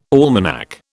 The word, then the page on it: almanac: British English